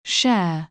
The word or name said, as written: share